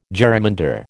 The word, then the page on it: jerrymander: American English